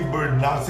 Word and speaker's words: burnout: Vamos começar então, vamos falar de síndrome de burnout. Você quer que chame de burnout? Burnout, assim, com AU. Você escolhe a pronúncia, o que importa é o seguinte.